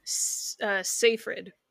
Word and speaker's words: Seyfried: Amanda Segfried. – Oh my god, what is? – I don't know how to pronounce her last name. – Seyfried. – Seyfried.